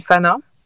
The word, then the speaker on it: F6NA: Ça s'écrit F6NA, ça se dit Fana.